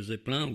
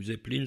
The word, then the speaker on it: Zeppelin: Un autre thème, enfin, de ce film, donc Zeppelin ou Zeppelin selon la prononciation, qu'ils appellent Garden Party.